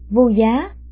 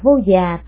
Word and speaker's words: Vô Giá: Vô Giá, còn đọc là Vô Già Thắng Hội, còn được phiên âm là Bát Giá Vô Sắc Hội.